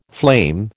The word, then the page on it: flame: American English